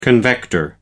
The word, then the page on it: convector: American English